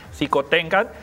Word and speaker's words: Xicoténcatl: Xicoténcatl. – Xicoténcatl.